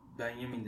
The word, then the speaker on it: Benjamin: Şimdi e, bu Benjamin dedi seçkin. Büyük ihtimal telaffuz öyledir. Ben Benjamin diye telaffuz ediyorum.